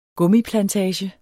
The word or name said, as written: gummiplantage